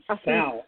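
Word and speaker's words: Assunção: How do you say his last name? Asuncao or Asuncao? Whatever. – Asuncao.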